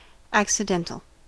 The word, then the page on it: accidental: American English